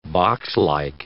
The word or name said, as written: boxlike